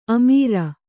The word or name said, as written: ಅಮರ